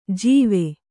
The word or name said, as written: ಜೀವೆ